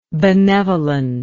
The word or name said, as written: benevolentness